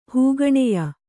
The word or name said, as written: ಹೂಗಣೆಯ